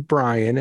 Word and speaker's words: Bryan: So if you text my name, Brian, and I spell it with a Y,